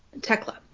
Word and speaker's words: Thekla: In fifth, it is Thekla. Is that how you pronounce it? – Thekla. Yeah, without the H.